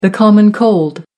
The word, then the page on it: common cold: American English